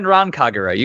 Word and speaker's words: Senran Kagura: All right, well, the first game that we're going to talk about is called Senran Kagura. Did I say it right? – Yes, yeah, Senran Kagura. You got it.